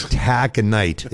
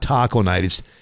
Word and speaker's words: Taconite: And every time that one came up, he'd call it Taco Night, and the program director would tell him it's Taconite. It's not Taco Night.